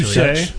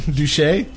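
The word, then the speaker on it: Deutch: But this film was directed by a guy named Howard Deutsch. – I think it might be pronounced Dutch. Actually, Duche. – Duche. – Howard Duche.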